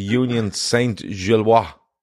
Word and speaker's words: Union Saint-Gilloise: Liverpool away in Belgium tonight to Union Saint-Gilloise. I'm being told is that's how you say it. I think that's fairly close.